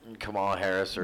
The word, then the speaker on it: Kamala Harris: with Kamala Harris, or is that how you pronounce her name? – Yeah.